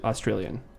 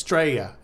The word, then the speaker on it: Australia: They don't even do the A in their country's pronunciation. It's Straya. No, it's Australia. There's a whole sound that comes before the Straya part.